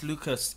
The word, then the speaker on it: Lucas: My name is Lucas. It rhymes with Pucus.